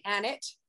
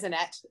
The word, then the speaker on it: Annett: It's actually Annett, but everybody says Annett. It's okay.